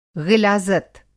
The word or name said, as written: ग़िलाज़त